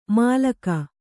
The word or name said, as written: ಮಾಲಕ